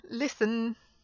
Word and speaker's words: listen: Words like listen and christen and glisten and listening or listened, all these things, you don't pronounce the T there.